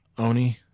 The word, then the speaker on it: Oni: The concept of the Ani was associated. Dude, with... Now you're pronouncing it wrong. Oni, sorry.